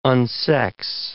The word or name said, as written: unsex